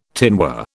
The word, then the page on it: tinware: British English